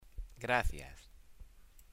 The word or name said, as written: gracias